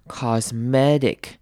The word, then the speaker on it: cosmetic: 好，后面呢 t 也是一样要弱化变成 d 的声音 cosmetic。 Cosmetic，有没有发现并没有把 t 这个轻音发出来？后面 t 后面的 i 也是一样要念成 i 的声音，短母音 i，所以是 cosmetic surgery。